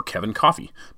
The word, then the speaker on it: Coffey: Kevin Coffey.